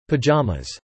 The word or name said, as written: pajamas